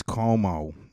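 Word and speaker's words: Cuomo: His name is Como, you know? You know, rhymes with blow-mo.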